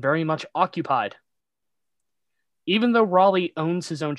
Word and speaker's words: Raleigh: It's pronounced Raleigh, not Rally or anything like that.